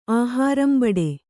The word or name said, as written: ಆಹಾರಂಬಡೆ